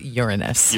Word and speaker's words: Uranus: Also, it's pronounced Uranus.